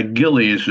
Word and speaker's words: Gillies: Yeah, Gillies is how you pronounce it.